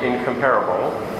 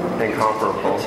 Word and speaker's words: Incomparable: Incomparable. – Well, I got the M-tass. – Maybe that's Canadian. – I got the M-tass on the wrong syllable. Yeah. Incomparable. Incomparable. Yeah, okay. It's one of those words.